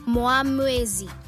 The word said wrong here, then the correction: Muamwezwi: Muamwezi. – And in Lozi, Muamwezwi. – Muamwezwi. Okay, it's Muamwezwi. Muamwezwi. Zwi, zwi. Muamwezwi. – And in Lozi, Muamwezwi. – Muamwezwi. Okay, Muamwezwi. That's how you say it.